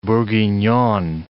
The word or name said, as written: bourguignonne